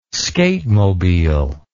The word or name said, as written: skatemobile